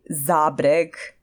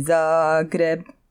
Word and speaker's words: Zagreb: Local kids often get it all wrong when they say Zabreg.